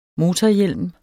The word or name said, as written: MOTORHJELM